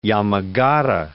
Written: Yamagata

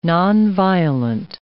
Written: nonviolent

